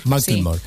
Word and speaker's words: Macklemore: McLemore. Macklemore.